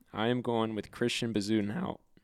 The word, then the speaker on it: Bezuidenhout: D-E-N. – Uh-huh. – H-O-U-T. – Oh, my. I mean, that's easy. – Bezuidenhout.